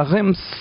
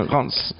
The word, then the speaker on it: Reims: Encore un exemple à Reims, tu vois, j'y arrive pas, tu vois, voilà. On prononce pas le M. – 30 ans de francophonie et j'arrive pas à prononcer Reims.